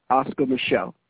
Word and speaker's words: Oscar Micheaux: Now, his name is spelled O-S-C-A-R, and he has a French last name, M-I-C-H-E-A-U-X, Oscar Micheaux.